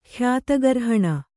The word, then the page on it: ಖ್ಯಾತಗರ್ಹಣ: khyāta garhaṇa